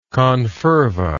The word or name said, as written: conferva